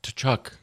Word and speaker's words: Tkachuk: I want to watch Tkachuk. I mean, there's no... – And you're going to pronounce the T.